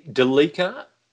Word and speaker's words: Delica: So you're going the Delica, as in Celica, rather than Delica Celica. – I think that's how the enthusiasts pronounce it.